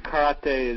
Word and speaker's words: karate: Yes, I speak Japanese, so karate is the correct pronunciation.